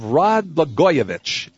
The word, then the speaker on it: Rod Blagojevich: The trial of Rod Blagojevich, and I've said it properly this time.